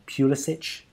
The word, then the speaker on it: Pulisic: They've got that young talent, Pulisic. I think I've said that wrong. – You haven't. It's perfect.